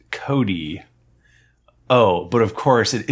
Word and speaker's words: Chode: Pronounce Cody, by the way. – Cody, C-H-O-D-E, Cody.